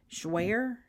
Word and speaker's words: Jouer: Okay, here's a question that asked me how I like the, I can't say it, Jouer? Is that how you pronounce it? J-O-U-E-R? – Yes.